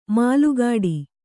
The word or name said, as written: ಮಲಗಟ್ಟು